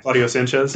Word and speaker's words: Claudio Sanchez: When we got the chance to talk to him, Coed, I can't ever say the name right. – Claudio Sanchez.